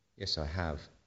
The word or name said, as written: i have